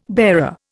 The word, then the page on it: bearer: Indian